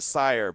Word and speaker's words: Sire: Is it Sears or is it Sire? But it's 50 Cent. They just came to agreement with 50 Cent on his spirits. So it's S-I-R-E. Is that Sire or is that Sear?